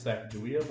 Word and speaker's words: Sacagawea: Sacagawea. The way we pronounce it, because we're white, is Sacagawea, but the true pronunciation is Sacagawea.